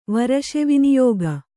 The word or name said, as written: ವರಶೆವಿನಿಯೋಗ